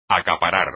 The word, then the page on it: acaparar: espagnol